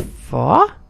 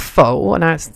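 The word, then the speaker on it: phở: I know it's pronounced fa, sort of, kind of. It's not faux.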